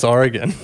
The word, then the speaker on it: Oregon: I just can't get over how you pronounce Oregon. – What's it? Oregano? What is it? What did I say? – Oregon. Like polygon.